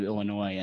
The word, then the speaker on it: Illinois: See, you pronounce the S sometimes and sometimes you don't. That's weird. Illinois. – A combination of Illinois and noise.